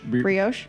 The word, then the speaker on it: brioche: B-R-I-O-C-H-E. – Brioche? – Brioche? – Brioche.